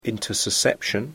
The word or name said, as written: intussusception